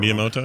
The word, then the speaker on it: Miyamoto: Miyamoto, like, oh, did I get his name right? I think I got it. – Miyamoto, right.